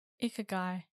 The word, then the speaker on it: ikigai: Ikigai. That's my vote. That's how you say it. – Ikigai.